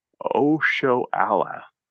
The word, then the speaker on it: Oshoala: Because I do think the threat of Oshala, I totally butchered that. Oshoala from Nigeria.